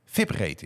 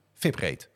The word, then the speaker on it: vibrate: Die leert ons dat de uitspraak vibrate is. – Oh, oké. Okay. – Ja, hij heeft dat even tussen haakjes gestuurd. Hij zegt, je moet het zo uitspreken, dubbele punt, vibrate.